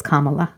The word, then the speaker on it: Kamala: I should start because I'm the one that was saying our Madam Vice President-elect's name wrong. I know that it's Kamala.